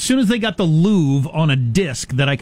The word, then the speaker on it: Louvre: Louvre and look at the paintings, it'd be exa- as as good as being there. I'm pretty sure it's pronounced Louvre.